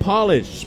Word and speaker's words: Palisch: Jake Polish, spelled P-A-L-I-S-C-H, pronounced like nail polish.